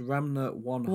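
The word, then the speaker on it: Ranma ½: Pronounced Ranma one half. – One half. Okay, fantastic.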